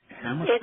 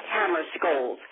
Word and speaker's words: Hammarskjöld: Dag Hammarskjöld. It's hard, you know, to spell as well as pronounce. Dag Hammarskjöld. H-A-M-M-A-R-S-K-J-O-L-D.